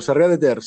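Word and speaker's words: Sarrià de Ter: Narcis, alcalde de Sarria de Ter, ¿lo pronuncié bien? Correcto, Sarria de Ter, sí, un pueblecito al lado de, de una de las capitales de, Cataluña, que es Girona.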